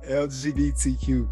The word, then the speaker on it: LGBTQ: Um, in all seriousness, um, we definitely need to like show love to um the L LBGD community. Um, uh, I ah, did I say that wrong? – LGBTQ community.